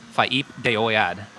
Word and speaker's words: Faip de Oyad: Yeah, so it's Enochian language, which is invented in the 16th century, but the song is called Faip de Oyad. Faip de Oyad, which means voice of God in the Enochian language.